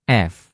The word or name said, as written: f